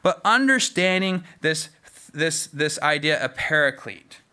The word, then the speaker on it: paraclete: So the actual word in Greek is basically pronounced paraclete.